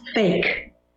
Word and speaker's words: PHEIC: I'm like, you know how that's pronounced? It's pronounced fake. – Fake. – P-H-E-I-C.